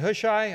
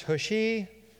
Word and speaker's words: Hushai: Hushai's deceptive advice. Some people pronounce it Hushai, others Hushi. I'm going to go, or Hushi. I'm going to pronounce it Hushai, okay? There's about three or four different pronunciations.